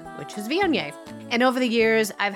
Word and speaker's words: Viognier: Start with the letter V. You can even do this like a little cheerleader cheer. Make a V with your hands. Then crisscross your arms like you're hugging the front of yourself. Own it. And then, yay, raise your hands above your head because we get so excited about it. Viognier. That's how we pronounce it.